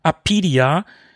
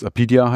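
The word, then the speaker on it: Apidya: Da haben wir Chris Hülsbeck auf der Amiga 40 nämlich sogar gefragt, wie es richtig ausgesprochen wird. Weil auch wir, was heißt auch, vielleicht habt ihr es ja immer richtig ausgesprochen, aber wir haben früher immer Apidia gesagt. – Und dann hatten wir auch eine Phase, wo wir Apidia gesagt haben, weil so steht es ja geschrieben.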